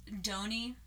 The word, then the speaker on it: Doheny: Ned Doheny, I want to say that's how you pronounce his name. It's D-O-H-E-N-Y. Could be... – Sounds right.